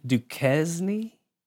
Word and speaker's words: Duquesne: Duquesne? – Yep.